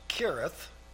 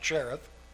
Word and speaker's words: Cherith: You'll excuse me if I pronounce it that way. I know that's not correct. It really should be pronounced Kirith, but it doesn't look that way in my Bible. It looks like Cherith.